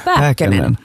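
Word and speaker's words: Pääkkönen: Och jag tänkte att vi måste prata om skådespelaren Jasper Pähkönen. Uttalar jag namnet rätt? – Pähkönen. – Pähkönen. – Perfekt.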